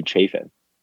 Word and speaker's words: Chafin: Did you just call him Shafin? – Yeah, how do you pronounce it? Chafin? – Are you kidding me? – Dude's Chafin? – You called him Shafin? – What do you call him? – His name is Andrew Chafin.